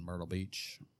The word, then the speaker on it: Myrtle Beach: We got a buddy that lives in Myrtle Beach. – It's nice. Yeah. I like Myrtle Beach. – Yeah. Just random. Yeah. It rhymes with turtle.